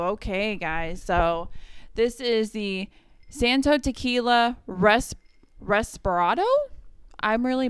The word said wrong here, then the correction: reposado: Reposado embodies the flavorful characteristics of 100% Blue Weber Agave, distilled and aged to perfection in oak barrels.